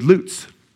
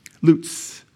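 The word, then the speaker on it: lutz (לץ): The fifth Hebrew word on the road to foolishness, and the last, you know, as bad as it gets, is lutz. Lutz. L-E-W-T-S, but pronounced lutz, the Hebrew word.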